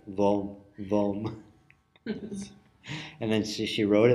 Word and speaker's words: Om: If you take Raja into Karnataka, this state that Mysore is in, central and western South India, and if you take Om into Karnataka, into the Kannada language, you get Vom. They put a semi-vowel. If a word begins with a vowel sound, they'll add a semi-vowel when they don't write it, the semi-vowel, but they pronounce it with the semi-vowel in front. So Om is Vom.